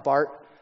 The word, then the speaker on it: Barth: And I know his name is pronounced Bart, like the Simpson, not like Barth Vader.